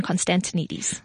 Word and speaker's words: Constantinides: And on Facebook, you can find my page under my name, Catherine Constantinides.